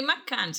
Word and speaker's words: McCann: Rory Magna. ¿Cómo eh. es? O sea, es que el apellido es rarísimo. Rory McCann. Perdón, si no pronuncia no bien aquí. Rory McCann.